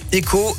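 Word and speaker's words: Ecoh: C'est donc l'ambition de cette appli Eco, Eco à la française, hein, comme dans écologie, ou bien Eco si on veut prononcer à l'anglaise, ça fait penser à il cohabite.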